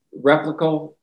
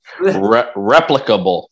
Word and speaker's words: replicable: That's why I'm interested in hauntings that are frequent and replicable, whatever. You know what I mean, people. – Replicable. – Replicable. I knew there was an extra accent or syllable in there.